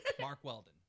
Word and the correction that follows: Mack Weldon: Mark Weldon. Mack Weldon. – You what? said it wrong.